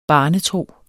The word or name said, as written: barnetro